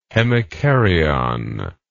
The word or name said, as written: hemikaryon